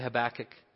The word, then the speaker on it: Habakkuk: Can someone say Habakkuk? All right, you guys pronounce it great.